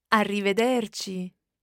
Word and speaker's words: arrivederci: Arrivederci.